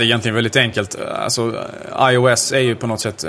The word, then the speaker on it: iOS: Är det rätt uttal förresten? Uh, ja, om du frågar Steve Jobs eh, så han uttalade det typ iOS.